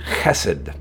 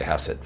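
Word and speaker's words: chesed (חסד): It's hard to say. I'm trying to say it like a good Jew. Chesed, we would say chesed.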